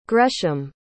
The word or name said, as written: gresham